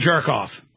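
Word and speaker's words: Chertoff: How do you pronounce his name? – His name is Chirkoff. Yeah, it was a former head of Homeland Security, Mr. Chirkoff.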